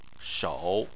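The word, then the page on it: shou: tone 3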